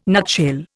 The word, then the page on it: nutshell: Indian